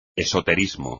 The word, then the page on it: esoterismo: espagnol